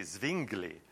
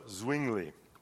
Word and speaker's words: Zwingli: And I thought, Zwingli was Swiss, so I thought, oh, it must be Zwingli. But I Googled the pronunciation, and they assured me it was with a W, an American W, not a German W. So Zwingli. So these two gentlemen, one was from Germany, Wittenberg.